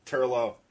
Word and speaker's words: Turlough: and the actor Mark Strickland, who played Turlough. – Oh yeah, sure, absolutely. Did I get the name right? – Turlough, yes.